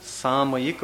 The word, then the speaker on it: सामयिक: देखो क्या नाम है इसका, हाँ गलत नहीं बोलना है उच्चारण, साम मां का उच्चारण नहीं होना चाहिए। सामयिक प्रतिमा,